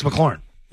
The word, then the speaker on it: McLaurin: Brown, Hardman, Metcalf, Johnson, McLaurin, and Renfro. And McLaughlin is. – Yeah, how do you say that? It's McLaurin, isn't it? – It's McLaurin. – Yeah. – Yeah, it's McLaurin.